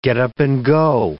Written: get-up-and-go

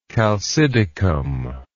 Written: chalcidicum

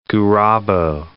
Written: Gurabo

